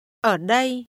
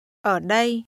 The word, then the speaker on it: Ở đây: Ở đây. Đây. Ở. Ở đây. Ở đây. – This is pronounced with falling, rising, and mid-level tones.